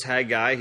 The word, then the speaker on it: Haggai: But for those of you who like to take notes, Haggai, his name in Hebrew is spelled C-H-A-G-I. That's not Chagai. That is a ch sound. The C-H in Hebrew is ch. Haggai is his name, and it is probably a shortened version of Haggaiah, which would translate Feast of the Lord.